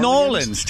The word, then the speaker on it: New Orleans: So it's New Orleans. I never hear. Is that how they pronounce it down there? New Orleans. – Nolans, Tom. Nolans.